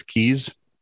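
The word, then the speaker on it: Caius: Did you know that C-U-I-S-E is Keys? – No.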